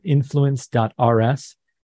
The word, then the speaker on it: influence.rs: Uh, also, my company is Influencers, which is spelled influence.rs. So it's pronounced influencers.